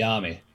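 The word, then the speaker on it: Dyami: Yes, Diami.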